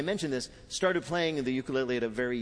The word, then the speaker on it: ukulele: the ukulele. – Peter's first question to Taimane How the hell do you pronounce ukulele? – Ukulele.